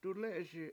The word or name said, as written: duLE7eSi7